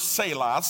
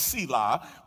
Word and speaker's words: Selah: There were those who will tell you, when you are reading your Bible and you see the word Selah, some pronounce it Selah, but it's correctly pronounced Selah.